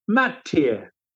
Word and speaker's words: McTeer: Not that Willie ever pronounced it that way. His family pronounced it McTeer. His real name was McTeer, which is, there are 37 different spellings of that in the Georgia area, white and black McTeers.